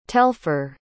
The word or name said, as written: telfor